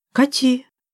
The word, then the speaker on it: Кати: «Кати!» — на местный лад с ударением на последний слог назвалась девушка.